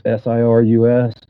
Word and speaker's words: Cyrus: It's my name, Cyrus LeVay. Cyrus is spelled like virus. It's Cyrus, and LeVay is spelled just like Uncle Anton, LaVey. So it's Cyrus LeVay.